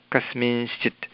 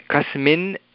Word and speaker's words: कस्मिंश्चित्: कस्मिंश्चित्, कस्मिंश्चित्, कस्मिन् इति न वक्तव्यं. नकारः अत्र नास्ति, नकारः नास्ति चेत् नकारः न वक्तव्यः अस्ति. कस्मिंश्चित् इति अस्ति, कस्मिंश्चित् इति न, कस्मिंश्चित्, कस्मिंश्चित्. अनुस्वारः, अनुस्वारस्य उच्चारणं तत्र अनुनासिकया, तत्र नासिकया भवति, अनुनासिकवर्णः अस्ति, नासिकया एव भवति. नाम वायुः नासिकया गच्छति, मुखेन न.